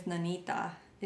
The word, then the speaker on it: Nanita: So my LLC name is Nanita. It's how you pronounce it, but it's spelled N-A-N-I-T-A.